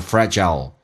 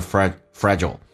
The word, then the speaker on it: fragile: 这个单词可以读成 fragile, 也可以读成 fragile.